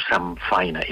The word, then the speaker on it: samfaina: Que quan aquesta N toca... amb una F, aquesta N es converteix en M a l'hora de pronunciar. i la gent diu Samfaina, i s'escriu Samfaina, però també és una qüestió fonètica, eh?